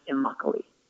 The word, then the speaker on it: Immokalee: It took me like a year to learn how to say it, but it rhymes with broccoli and it's pronounced Immokalee.